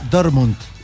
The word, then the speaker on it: Dortmund: Tengo que pronunciar bien porque si no después me critican. Borussia Dortmund, ¿ah? No, Dortmund con D, ante el Villarreal a las 6 de la tarde.